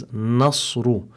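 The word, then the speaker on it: nasru (نصر): So be careful, make sure that the noon is muraqqaqa, so that you pronounce it as nasru.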